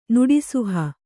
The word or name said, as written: ನುಡಿಸುಹ